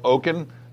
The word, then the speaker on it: Okun: At any rate, I want to first introduce and welcome to the show Dr. Michael Okun.